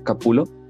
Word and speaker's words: Capullo: Por ejemplo, yo sigo a uno que es muy bueno, que me encanta cómo dibuja, que se llama Greg Capulo, o Capullo, se escribe, y dibuja muchos cómics de Batman.